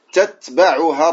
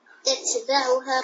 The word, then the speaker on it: تتبعها: c'est un peu lourd لكن faut que tu prononces chaque lettre à part tu dis تتبعها الرادفة تتبعها الرادفة